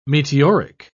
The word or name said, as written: meteoric